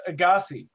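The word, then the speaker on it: Agassiz: It's pronounced Agassi. – Oh, but there's like a Z-I-S at the end or something. – S-I-Z. A-G-A-S-S-I-Z. – S-S-I-Z.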